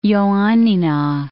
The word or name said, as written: Ioannina